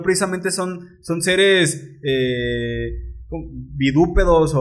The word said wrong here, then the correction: bípedos: Bípedos. Bípedos, perdón, bípedos, qué pendejo.